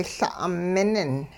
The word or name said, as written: La' minin